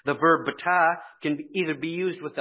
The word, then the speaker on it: batah: If you would like to put this in your notes, it's spelled B-A-T-A-H, B-A-T-A-H, batah. And here's what the theological word book says about this word trust coming from the Hebrew word batah.